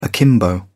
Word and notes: akimbo: British English

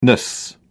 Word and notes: ness: British English

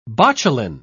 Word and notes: botulin: British English

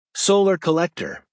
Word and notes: solar collector: American English

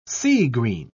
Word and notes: sea-green: British English

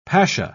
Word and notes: pasha: British English

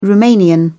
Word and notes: romanian: British English

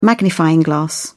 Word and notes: magnifying glass: British English

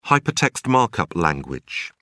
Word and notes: hypertext markup language: British English

